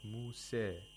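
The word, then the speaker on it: Musa (موسى): For example, the first difference is in the word Musa. He doesn't say Musa, he says Musa, which is called Taqlil.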